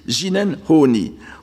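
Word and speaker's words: jinen honi (自然法爾): Il s'agit de ce terme que je prononce à la japonaise parce qu'il est plus facile de le prononcer ainsi, jinen honi, où le sens du premier terme, le jinen, est renforcé par le second, honi, qui est lui purement bouddhique.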